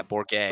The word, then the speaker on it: Bourget: Yeah, Bourget. – Like Gerald. Bourget. – Listen, man. I'm not French, man. – It's just like Gerald.